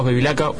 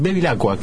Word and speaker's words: Bevilacqua: Está con nosotros Carlos Bevilacqua. Buenas noches. Bevilacqua, quise decir, y ahora lo digo bien.